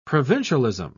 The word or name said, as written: provincialism